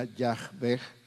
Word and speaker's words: Yahweh: la pronunciación correcta se perdió. Lo más cercano sería Yahweh y eh, como está escrito en nuestra Biblia, en la versión Reina Valera 1960, es Jehová, mismas consonantes, otras vocales.